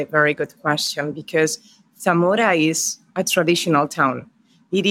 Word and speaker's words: Zamora: So your hometown is Zamora, Spain.